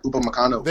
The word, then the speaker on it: Upamecano: Yep, Upamecano. – There you go. Thank you very much. Thank you. Yes. Upamecano is also it's also been rumored by the higher up journalist that's our next poach.